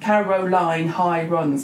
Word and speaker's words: Caroline Hirons: Caroline Hirons.